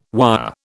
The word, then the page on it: wire: British English